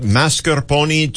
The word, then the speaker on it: mascarpone: Yeah, that whipped topping, it's kind of, was it mascarpone cheese? – Yes, very, very good pronunciation there.